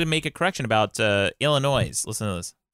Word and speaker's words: Illinois: As a resident of the city of Chicago in the state of Illinois, you don't pronounce the fucking S at the end of Illinois.